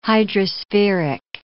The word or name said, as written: hydrospheric